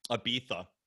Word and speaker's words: Ibiza: Is that the way you have to pronounce it? Ibiza. – Ibiza.